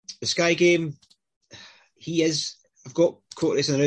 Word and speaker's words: Courtois: Courtois.